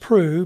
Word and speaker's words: Proulx: It's pronounced Prue, but it's spelled P-R-O-U-L-X.